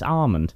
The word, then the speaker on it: almond: A, I'm not sure that would really go well in Magnum, and B, I am morally opposed to anyone that pronounces the L in almond. – Almond? – Yeah. – Well, I'll see a minute pronounce almond. – It's wrong, it's fucking silent, it's almond.